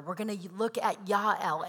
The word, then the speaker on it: Jael: Her name is Jael, but I'm going to pronounce it as Yael, which is the Hebrew pronunciation. And if you call her Jael, that's fine. That's what most people say.